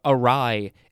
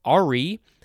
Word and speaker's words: awry: Next we have awry, A-W-R-Y. It looks like it would be pronounced ari, but that's not how it's pronounced.